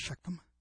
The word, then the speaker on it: Shechem: pronounced Shechem, and unto the plain of Moreh, and I'll get into that.